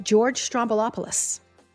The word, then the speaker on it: George Stroumboulopoulos: But it's George Stroumboulopoulos. And that's how you say his name.